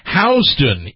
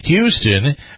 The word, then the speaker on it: Houston: You may know Houston, Texas, but in New York City, the street is called Houston, even though it's spelled the same. It's one of the 14 most mispronounced locations in the United States. That's according to a report from Business Insider that says pronouncing it Houston, as in the Texas city, is the quickest way to get yourself labeled as an outsider.